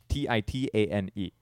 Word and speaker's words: Titane: It's titanium in French. Titane.